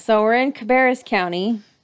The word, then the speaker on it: Cabarrus: And it is, in fact, pronounced Cabarrus.